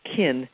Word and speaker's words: cynn: And the word kindness comes from an Anglo-Saxon word which is spelled C-Y-N-N, which was pronounced kin, which means kin, like your family.